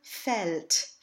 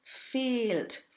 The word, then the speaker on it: fällt: First, the A umlaut is always transcribed phonetically as the open epsilon e. In this case, a short open e, because it's followed by a double consonant. If you pronounce this vowel too closed, we get the word fehlt, from fehlen, to miss, which would completely reverse the meaning of this phrase.